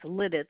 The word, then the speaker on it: Lititz: It's Lititz.